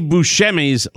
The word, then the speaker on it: Buscemi: Steve Buscemi's last name, properly pronounced, should be Buscemi. That's the way people pronounce it, but it should be Buscemi. That's the way you're supposed to do it.